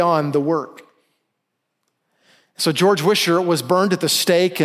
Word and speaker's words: Wishart: But I was introduced to a guy that I had never heard of, a guy named George Wishart. I've also heard his name pronounced Wizard.